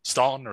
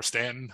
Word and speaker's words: Staunton: Some of these officers find quarters as far away as Staunton or Stanton, depending on if you're from Virginia or not, how you pronounce that the town in the upper Shenandoah Valley.